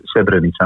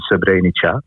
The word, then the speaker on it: Srebrenica: De klemtoon ligt voor anders. Okay. Het is Srebrenica in plaats van Srebrenica.